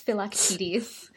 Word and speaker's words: Philoctetes: It's Philoctetes. – Philoctetes. It's also that I read Song of Achilles this year, which is so wonderful, by Madeline Miller. I'm obsessed with Circe and Song of Achilles, but Philoctetes is so different.